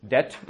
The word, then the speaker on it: debt: Dluhy jsou debt, mm, anebo debts. Pozor, to by tam vůbec, vůbec nesmí být. Ani trochu se nesmí vyslovovat.